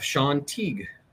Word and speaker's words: Shawn Teague: I'm not joined by Justin today, but have Sean Teague.